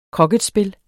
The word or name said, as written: kroketspil